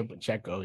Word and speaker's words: Pacheco: Is it Pacheco? Is that how they say his name? – Yes, Isaiah Pacheco.